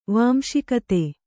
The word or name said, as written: ವಾಂಶಿಕತೆ